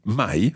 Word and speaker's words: mai: It sounds a little funny because we've got the hai, the part of avere for tu, and then mai, which rhymes with it.